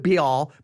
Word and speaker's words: Baal: I'm not here to get into an argument how to say it, but just say, I'm going to go with Baal because B-A-A-L, I think it works.